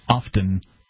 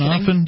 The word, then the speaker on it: often: And so good news often. – Good vibes only. – Do you pronounce the T in often? Do you say often? – Yeah, I think I do. Often.